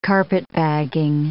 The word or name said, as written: carpetbagging